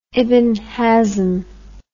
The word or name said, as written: ibn hazm